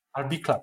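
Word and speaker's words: Albicla: Niedawno słuchałem prezesa PiS Jarosława Kaczyńskiego, który w wywiadzie dla portalu Albikla, tak to się wymawia? – Chyba tak. – Albikla, tak.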